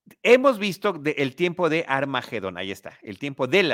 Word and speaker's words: Armagedón: espérame, eh, ah espérame, espérame, espérame, ¿Se pronuncia Armagedón? Sí, no tiene U. En español, no tiene U. Armagedón.